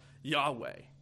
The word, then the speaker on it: Yahweh: So this is God's divine name, which a lot of Hebrew scholars will say is pronounced Yahweh.